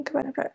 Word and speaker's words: Myddfai: It is, she says, looking, Myddfai, because the double D is a letter on its own in the Welsh alphabet, and it makes a sound. – Okay. – So you pronounce it with mov, like mother, and V as in like V-E.